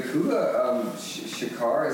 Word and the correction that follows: Kula Shaker: is that how you say it? That song? – Kula Shaker.